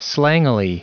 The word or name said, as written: slangily